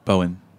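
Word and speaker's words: Bowien: Danny Bowine's place. Is that the right way to pronounce Danny? – Bowen.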